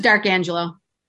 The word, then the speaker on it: D'Arcangelo: It's just D'Arcangelo.